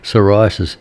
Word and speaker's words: psoriasis: That's especially for the skin's elasticity, and are known to play a role in inflammatory disorders such as psoriasis. That's how it's pronounced, but it's got that little P in front of the S.